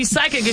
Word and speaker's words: psychic: I know you're so you're right, because when we see psychic, it's spelled P-S-Y, right? – I couldn't tell you. – Yes, yeah. This, yep. – So the P is silent.